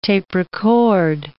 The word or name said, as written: tape-record